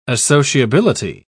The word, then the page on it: associability: British English